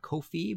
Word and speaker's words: Ko-fi: Ko-Fi rhymes with no fee.